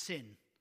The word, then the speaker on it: Tsin: This desert of Sin here had nothing at all to do with it being a morally bad place, because sin comes from the Hebrew word spelt T-S-I-N, pronounced sin.